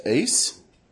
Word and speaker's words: eis: And often there's a little Greek word pronounced eis, that means into, and it's often used in the Bible in the phrase believing into Jesus, or believing into Christ, or believing into the Christ.